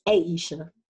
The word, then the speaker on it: Aisha: Aisha.